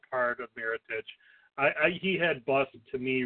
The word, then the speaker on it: Mirotic: Mirotic.